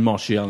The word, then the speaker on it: Martial: So I enter into, or however you pronounce his name, I'm saying Martial in an excessive Martial. French manner. Martial.